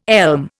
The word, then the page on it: elm: Indian